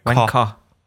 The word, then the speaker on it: Wanker: Die Betonung, ja, und das Highlight ist bezogen auf K. Wanker. – Wanker.